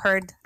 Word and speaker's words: Heard: Que es el caso de Johnny Depp, Johnny Depp y Amber Hicks. cómo se pronuncia? Carol, no se pronuncia la Yo digo Heard, Amber Heard, como, no sé.